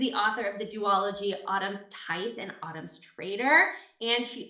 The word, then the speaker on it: Autumn's Tithe: And I just finished her first book in the duology, Autumn's Tithe.